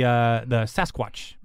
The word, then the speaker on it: Sasquatch: The bookmark was about the Sasquatch."